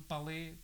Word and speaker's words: Palais: Este edifício aqui é o chamado Palé, que se escreve Palais, que é o centro.